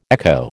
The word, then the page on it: echo: American English